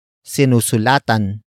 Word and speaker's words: sinusulatan: Word for word it's sinusulatan ng estudyante ang guro.